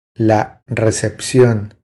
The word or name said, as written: La recepcion